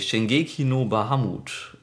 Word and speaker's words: Shingeki no Bahamut: Dann mach du doch gleich mal weiter mit deinem Anime Shingeki no Bahamut.